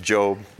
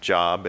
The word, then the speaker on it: Job: And that is pronounced Job, not Job. It's not a place where you find out how to do a resume or something like that.